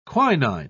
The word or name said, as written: quinine